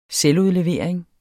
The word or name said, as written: selvudlevering